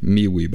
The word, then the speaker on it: MeWe: The name of the group on MeWe, MeWe, by the way, is spelled M-E-W-E.